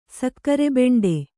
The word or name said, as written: ಸಕ್ಕರೆಬೆಂಡೆ